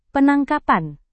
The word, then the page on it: penangkapan: Indonesian